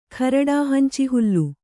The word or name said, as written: ಖರಡಾಹಂಚಿಹುಲ್ಲು